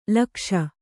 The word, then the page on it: ಲೋಕೇಶ: Kannada